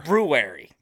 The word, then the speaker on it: February: Yeah, there's that silent R. It's February. – Yeah. Why is there a silent R? – It's not silent. You're supposed to pronounce it.